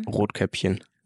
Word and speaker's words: Rotkäppchen: Rotkäppchen.